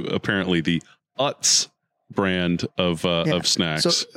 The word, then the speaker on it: Utz: No, so I guess it's pronounced Utz is the chip company in Pennsylvania.